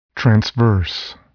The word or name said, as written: transverse